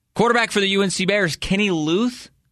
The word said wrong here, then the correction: Leuth: L-E-U-T-H. Coach Lamb has said as much. It's Kenny Leith. It's Kenny Leith.